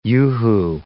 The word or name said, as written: yoo-hoo